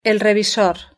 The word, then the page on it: el revisor: European Spanish